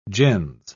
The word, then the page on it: gens: British English